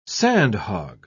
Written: sandhog

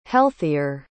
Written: healthier

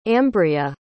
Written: ambrea

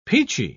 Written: peachy

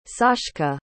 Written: sashka

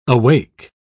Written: awake

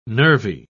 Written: nervy

